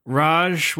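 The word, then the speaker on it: Ra's: Raj was how they said it in the movie, and Raish is apparently how it's supposed to be.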